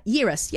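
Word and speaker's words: Yarros: How do we say it? It's a hard word to say. Yeris, yep.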